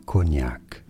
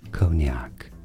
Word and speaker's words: Cognac: If only we had access to pronounce this name in French, it is said as Cognac. So, in English, Cognac. And now you know.